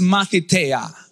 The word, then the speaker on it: mathiteia: And the word, I want you to write it down, see if you can, is matheteia. Matheteia. I know, right? Matheteia. It's like Matthew, M-A-T-H-I-T-E-I-A, with an accent on the I.